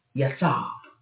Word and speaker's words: Yatsav: And when you look that word up, Yatsav. That's how you say it in the Hebrew.